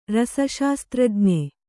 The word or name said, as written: ರಸಶಾಸ್ತ್ರಜ್ಞ